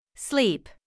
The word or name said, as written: sleep